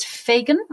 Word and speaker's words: faighan: It's faegan. I don't know if I'm saying that right. F-A-I-G-H-A-N. – It sounds good to me.